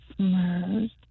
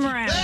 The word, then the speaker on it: Mraz: No, say it like, do what I said. Remember the gift? Not frankincense but. – Myrrh. – Yeah. Yep. And now the state? – Jason Mraz.